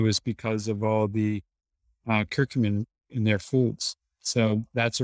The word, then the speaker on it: curcumin: Curcumin. Curcumin, is that how you say it? Yeah. – Yeah, curcumin.